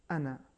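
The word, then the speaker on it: أنا: لیکن اگر ہم ملا کے پڑھیں انا انا فعبدون, یعنی میری عبادت کرو, تو الف نہیں پڑھا جائے گا. – الف نہیں پڑھا جائے گا, صرف نون اور نون پر زبر, زبر کا تلفظ ہوگا لیکن الف کا تلفظ نہیں ہوگا.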